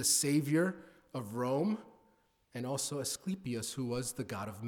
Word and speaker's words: Asclepius: Asclepius, as it should be pronounced, Asclepius, I keep getting that wrong.